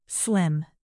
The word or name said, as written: slim